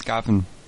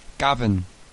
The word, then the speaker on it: Gavin: My name's Gavin. – I'm sorry? – Gavin. – Can you spell it for me? – It's a G-A-V-I-N. It's a Welsh name. – Awesome. What's your last name? – It's Days. D-A-Y-S.